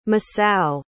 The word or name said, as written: Masao